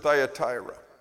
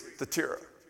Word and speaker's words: Thyatira: That's the English pronunciation. In Greek, it's Thyatira. Thyatira. But it sounds weird saying that. So I'm going to continue to use the English version because we're more comfortable with that.